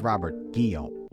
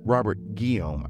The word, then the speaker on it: Robert Guillaume: I believe that's Robert Guillaume. – Robert Guillaume. I added a Gilly. I felt like he needed more syllables in his name.